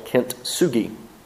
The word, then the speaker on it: kintsugi: It's called kintsugi. It's one word. K-I-N-T-S-U-G-I. Kintsugi, I believe, is how it's pronounced, but I don't know because I'm from Mississippi and we can't pronounce those words.